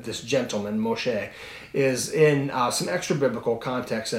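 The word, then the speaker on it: Moshe (משה): And one of the things that I discover is they believe, through extra-biblical texts and history, that Moses, his name would have been pronounced Moshe,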